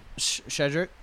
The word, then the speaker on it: Shedrick: Cedric did. – Shedrick. – Mm-hmm. Am I saying it wrong? – Yeah, you're saying Cedric. It's Shedrick. – Shedrick. – Like Shed and then Rick.